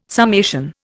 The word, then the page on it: summation: Indian